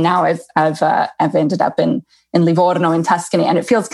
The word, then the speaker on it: Livorno: And I, by the way, I'm so impressed that you can roll your R's like that because you didn't learn that in Menlo. You know, you didn't learn that in California. So the fact that you can go Livorno is just beautiful.